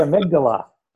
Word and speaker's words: amygdala: And in your book, there's a theme that you talk about how to tame your, I hope I said this correctly, amygdala? – Oh, I love that. The amygdala.